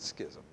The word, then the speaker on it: schism: That word is pronounced differently by different people. I pronounce it schism. It's S-C-H-I-Z-M.